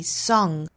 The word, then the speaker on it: song: 那比如说像 S-I-N-G, sing, 包括像 S-O-N-G, song, 这些都是包含后鼻音的。所以读的时候不能读成 sin 或者是 son, 应该是 sing 和 song。